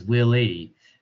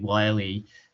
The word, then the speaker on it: Wiley: Well, the judge says Willie, and Will Forte's character Willie says it's actually Wiley.